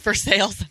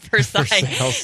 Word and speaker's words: Versailles: Yes, it's pronounced Versailles, not Versailles. That's a real thing.